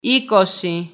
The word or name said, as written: είκοσι